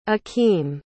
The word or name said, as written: akim